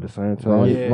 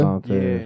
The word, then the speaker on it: DeSantis: Sorry if I said his name wrong. – Ron DeSantis. – Ronnie boy? – DeSantis. Yeah.